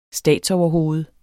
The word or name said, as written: statsoverhoved